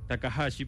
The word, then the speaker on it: Takahashi: Su nombre es Kenshi Takashi Takahashi, perdón.